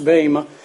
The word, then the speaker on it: Bema: And it's a word that comes from directly from the Greek, and that's the way it's pronounced, although if you would prefer to say Bema, I don't care, really, but it's pronounced Bema.